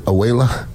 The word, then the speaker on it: Abuela: You walk in, it's got kind of a grocery store, and you walk in the back, and then, is it Abuela? Is that how you pronounce it? Abuela? – Abuela.